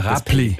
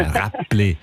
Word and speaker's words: Räppli: Du hast es mit einem B ausgesprochen. Ja. – Räppli. – Ja, Räppli.